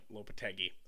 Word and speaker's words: Lopetegui: Julen I don't know how to say his last name to be honest. Lopetegui. Yeah, yeah, Lopetegui.